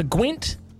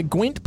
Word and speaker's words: Gwent: Well, a story just came across my radar of the Gwent police. Mm-hmm. It's pronounced Gwent, isn't it? Not Gwen or something. It's Gwent. – G-W-E-N-T.